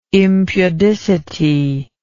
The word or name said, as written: impudicity